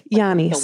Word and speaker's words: Giannis: Okay, but I'll tell the Giannis story. I keep saying his name wrong. That's so mean. He's such a sweetie. How do I say it? – Giannis.